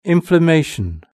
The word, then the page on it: inflammation: British English